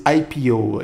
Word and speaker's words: IPO: o Cardoso tá, tá organizando aí pra gente abrir esse IPO. Aí. É assim que fala? Falei certo Isso, agora? – Isso, brilhou.